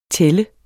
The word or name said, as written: tælle